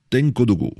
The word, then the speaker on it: Tenkodogo: Yameogo nous écrit de Tenkodogo. Tenkodogo, voilà. Excusez-moi d'avoir écorché le nom de cette ville.